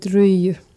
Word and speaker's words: ryijy: We call it ryijy. – Ryijy. – Ryijy. – Mm-hmm. – So that's how you say that. Mm-hmm. I've always wondered. – We just called it ryijy. We were pronouncing it wrong.